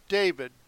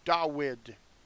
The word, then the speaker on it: Dawid: Dawid. Not David. Dawid. With a waw. I pronounce it correctly, you know. That's the way it is.